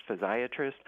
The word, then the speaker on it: physiatrist: So it can be pronounced physiatrist, which sounds a lot like psychiatrist or podiatrist.